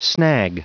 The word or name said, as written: snag